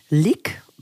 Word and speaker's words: LiQ: Also dieses ähm, Lick, denke ich mal, wird es ausgesprochen, L-I-Q, ja. ja.